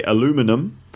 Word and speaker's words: aluminum: And that's because between American English and British English, they pronounce the words differently. So in Canada or in America, they'd say aluminum. And in Britain, we'd say aluminium.